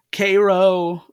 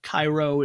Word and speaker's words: Cairo: And I saw people say Cairo, Chiro, Cairo, like the town in Illinois, which is pronounced differently than Cairo in Egypt.